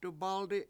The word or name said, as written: dubAldi7